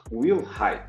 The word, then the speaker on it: Wilhite: Eu quero que aproveitar, na verdade, pedir uma licença, porque eu pronunciei o nome do Stefan errado. É Stefan Will, Wilhite, desculpe aí, com perdão da pronúncia.